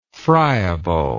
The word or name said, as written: friableness